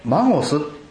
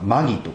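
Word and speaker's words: magos: ここで博士と書いてありますけれども、博士と言いますのはギリシャ語でマゴスと言います。マゴスとかマギとか、後々これ英語でマジックという言葉になっていくんですけどもね、まあ、このマゴス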